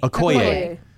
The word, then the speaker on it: Okoye: how do you pronounce that general's name? Oyoki? – Okoye. – Okoye.